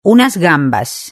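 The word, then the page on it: unas gambas: Spanish (Spain)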